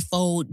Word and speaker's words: faux: You know, people are like, oh, I can't wait for all you phony or fox. Fox. How do you say foal? – How do you say F-A-U-X? Fox. – It's faux. – Is it faux?